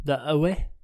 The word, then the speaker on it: The OA: The OA? – The OA.